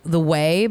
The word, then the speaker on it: Ouai: She has her own very successful now hairline called the the way, but it's spelled O U A I, mm-hmm, which is mm-hmm.